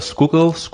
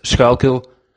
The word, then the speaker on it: Schuylkill: Specifically, there's a river there, and I believe the locals, they call it the Schuylkill, something like that. But the word is of Dutch origin, and as a native Dutch speaker, I always feel the urgent need to correct locals and my wife that we would actually pronounce it Schuylkill.